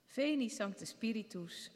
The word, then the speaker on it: Veni Sancte Spiritus: Ja, Veni Sancte Spiritus, Tui Amoris, Ignem Accende. Zo spreek je het uit. Veni Sancte Spiritus, Veni Sancte Spiritus.